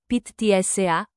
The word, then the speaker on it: pizza: Se pronunce, di lunga e accento acuto la tu, le g più e fight, di lunga e accento acuto, pizza. Se pronunce, pit tsa, avec double z qui fight, ts,